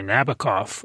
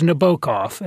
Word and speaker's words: Nabokov: Most Americans, a lot of English people, anybody who's listened to the Sting song say Nabokov, but it was Nabokov, and he wasn't much of a stickler.